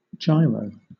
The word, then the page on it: gyro: British English